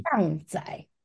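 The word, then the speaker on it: 氹仔: 然后他香香姐有去特别查他中文的怎么念注音的，嗯，荡仔的昂荡荡仔。 – 就是荡来荡去的荡嘛，对不对？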